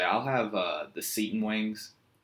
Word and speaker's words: seitan: But no, so we learned about tempeh and veganase or veganase, as people call it, and um, seton is what we say, but I think it's pronounced seitan.